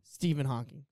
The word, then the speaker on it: Stephen Hawking: What did I say? – Steve Hawkins. – And what was his name? – Stephen Hawking. – So like, I said it right, but wait. – Instead of I said Steve Hawkins and says Stephen Hawkins. – Yeah. – Did you just correct me for missing an N? – Well, like it's different.